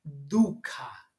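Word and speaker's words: dukkha: lo voy a decir en, una, la manera como lo, como lo pronuncian, duca, d u que normalmente traducen como sufrimiento,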